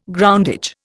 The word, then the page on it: groundage: Indian